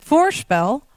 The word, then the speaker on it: voorspel: And the same word, if you put the stress on the first syllable, voorspel, it's a noun, and it means foreplay.